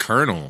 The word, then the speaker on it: colonel: What's up with the word colonel? Why is it spelled like that? Where do you get an R out of that? C-O-L-O. It's like colon L. How is it colonel?